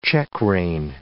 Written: checkrein

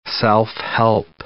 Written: self-help

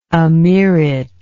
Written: emeerate

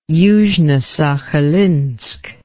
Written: yuzhnosakhalinsk

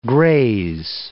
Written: graze

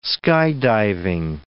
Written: skydiving

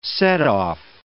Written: setoff

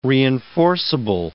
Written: reinforceable

